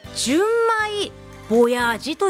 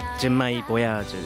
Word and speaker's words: 純米ボヤージ: あのね、日本酒&アニメバー、これは純米ボヤージと読むんですよね。 – はい、純米ボヤージですね。